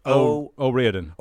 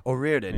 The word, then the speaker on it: O'Riordan: Say her name again. – Cot. O'Riordan. – O'Riordan. Yeah. Thank you.